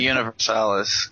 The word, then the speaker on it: Universalis: Universalis.